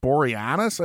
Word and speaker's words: Boreanaz: It runs from 99 to 2004, and the show details the ongoing trials of Angel David Boreanaz, I think is how you say his name.